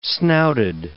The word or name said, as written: snouted